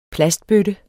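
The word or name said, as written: plastbøtte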